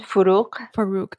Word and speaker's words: Farrukh: Farrukh. – It's almost like a K at the end. Farrukh.